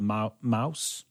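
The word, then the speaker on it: Maus: And then the third, again, there's a comic, a very famous one, a graphic novel called Mouse. Is that it? Is that the correct pronunciation? – Yeah.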